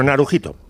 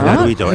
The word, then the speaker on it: Naruhito: Bueno, Narujito, Naru, o Naruito, no sé cómo se pronuncia. – Naruito, ¿no? Naruito. No, Narujito es...